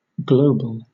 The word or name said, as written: global